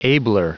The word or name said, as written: abler